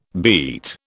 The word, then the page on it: beet: British English